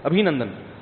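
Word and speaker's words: ابھینندن: کسی نے بتایا ابھی نندن ہے, ابھی نندن نہیں ہے, صحیح تلفظ کیا ہے بھائی, ابھی نندن.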